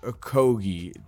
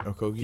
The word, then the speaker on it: Okogie: Josh Okogie. I said that last name right? Okogie? – Yeah, you said it right.